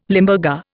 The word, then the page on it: Limburger: Indian